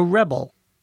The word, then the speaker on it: rebel: And the person who rebels is called a rebel. A rebel, stress on the first syllable, is the person who rebels. The verb, stress on the second syllable.